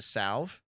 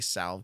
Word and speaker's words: salve: I could be using the targeted pain salve. I always say salve because there's an L in there. It's a silent L. Salve.